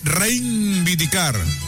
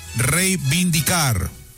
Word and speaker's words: reivindicar: No se dice reivindicar, se dice reivindicar. Sin N, no le pongas N ahí.